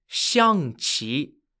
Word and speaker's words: Xiangqi: Xiangqi. Xiangqi is spelt X-I-A-N-G-Q-I.